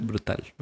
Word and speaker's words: Brutal: ¿Se pronuncia Brutal? – Brutal. – Brutal o Brutal, bueno, como quieran.